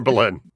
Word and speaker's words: Belen: Tara or Tara? Baleen or Balin? – Exactly. It's Balin. It's Balin.